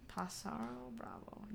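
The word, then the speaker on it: Pássaro Bravo: Pasaravo Bravo. – Pasaro Bravo. You added six syllables to that first sentence.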